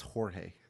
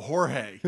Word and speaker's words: Jorge: I don't think maybe that's not pronounced George. – I don't believe that's no, that's Jorge. – Oh, Jorge.